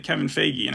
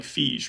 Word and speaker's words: Feige: But you said Kevin Feige, and I think we've always pronounced it that way. It's also like Feige, right? – Yeah. – Like, I think that's how it's said too. Dialectical behavioral therapy, that's what they're technically doing in that montage.